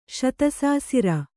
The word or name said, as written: ಶತಸಾಸಿರ